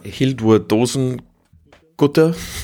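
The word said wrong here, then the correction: Hildur Guðnadóttir: Hildur Gurtner-Dottir heißt die.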